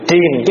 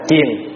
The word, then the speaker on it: triền: Chữ triền chứ không phải là chiền. Mình nói riết cái chùa triền. Đúng chữ phải đọc là chùa triền.